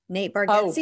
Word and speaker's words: Bargatze: It's not Getsky. Yeah. It's not Ski. – No, Rob. If Rob was here, he would say it correctly, because he always makes the Tennessee kid. – Yes, he always like, he's like, no, he corrects me all the time on it. He's like, no, there's no Ski in it.